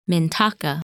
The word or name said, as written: Mintaka